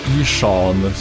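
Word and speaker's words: Ihsahn: De är från början kompband åt Ishan som det tydligen uttalas eh, sångare och gitarrist i Emperor.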